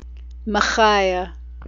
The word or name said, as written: mechaiyeh